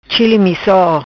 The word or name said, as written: Chili misso